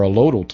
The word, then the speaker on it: allodial: Well, and a lot of people have gone the allodial title route, or allodial title. Well, which is the correct pronunciation on that? Allodial? – Allodial title. – Allodial title. Okay.